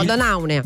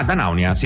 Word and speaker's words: Danaunia: No, Danaunia, scusami, Danaunia, come l'hai detto tu, con l'accento sulla prima A. – Ah, Danaunia.